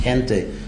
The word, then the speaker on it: gente: The Latin for jana is just Gentile, same word, which means the people, gente in Spanish, pronounced gente, but it's spelled gente in Spanish or Italian or Portuguese, which just means people, gente, janta, jantu in Sanskrit, same word, jantu, gente, or jana, people.